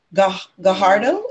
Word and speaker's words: Gajardo: Miss Pacific Time, Honey Gajardo, is that it? – Yeah.